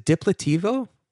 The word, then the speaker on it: Diplateevo: is it Diplativo? – That is correct.